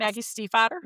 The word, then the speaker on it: Stiefvater: Did I say her name right? – Place. – Yes. – Maggie Stiefvater? – Yeah, mm-hmm. Okay.